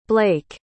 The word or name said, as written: blake